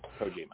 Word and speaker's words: Kojima: Who the fuck Kojima. – Is John Moxley going? – Kojima.